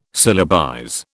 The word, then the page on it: syllabize: British English